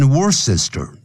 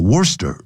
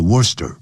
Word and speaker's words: Worcester: Then，然后呢，there are the areas of the Bagrivia Valley， Dumbarville，还有 Wellington， Worcester，这个不要念 Worcester，叫 Worcester 啊，C-E 不发音叫 Worcester。